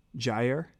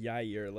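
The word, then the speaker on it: Jaire: Jair. – Okay. I thought you said Jair, like a soft J, like he's Spanish or something.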